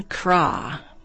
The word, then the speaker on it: Cras: Next, the music of Jean Krah, a 20th century French composer. The last name is spelled C-R-A-S.